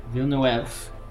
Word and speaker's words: Villeneuve: Villeneuve. Good pronunciation.